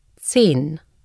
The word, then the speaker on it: Zehn: The letter Z is pronounced in German like TS in the English word nuts and the double Z in pizza. Listen and repeat. – Zehn.